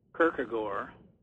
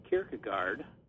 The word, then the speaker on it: Kierkegaard: Well, the Danes would say Kierkegaard, and Americans say Kierkegaard. And I compromise. I usually say Kierkegaard, which isn't either good Danish or good English.